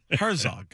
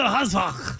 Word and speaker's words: Herzog: Is it pronounced Herzog? – There's no H in Herzog? – There might be a little H. – There should be H. – Why do rabbis do that? They do the Herzog.